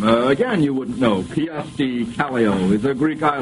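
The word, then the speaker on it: Kyösti Kallio: I'll repeat that. Kiyosti Kallio, I'll spell that, K-Y-O-S-T-I-K-A-L-L-I-O, is a Greek island, a dictator of Peru, president of Finland, or the name of a Japanese political party.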